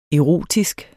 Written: erotisk

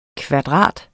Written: KVADRATERNES

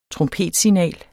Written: trompetsignal

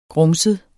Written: grumset

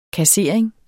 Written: kassering

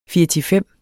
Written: firtifem

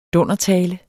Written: dundertale

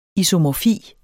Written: isomorfi

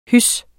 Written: hys

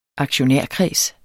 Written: aktionærkreds

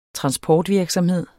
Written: transportvirksomhed

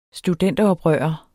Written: studenteroprører

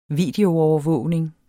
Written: videoovervågning

